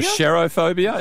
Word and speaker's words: Cherophobia: What's interesting is the performance has a name, which is Cherophobia. I think I'm pronouncing that correctly. – Cherophobia? – Or Cherophobia, as in C-H-E-R-O. Phobia, right? – Which is a fear.